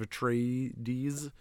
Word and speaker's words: Atreides: We got Doctor Doom number 10 and regular Dune House of Atreides. Ah, I've got Atreides. Would you like one? That's how it's pronounced.